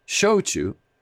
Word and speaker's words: shochu: A is A, E is E, I is E, O is O, and U is U. It's easy. S-H-O-C-H-U, shochu.